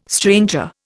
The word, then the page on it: stranger: Indian